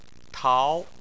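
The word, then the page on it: tao: tone 2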